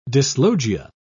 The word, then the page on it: dyslogia: British English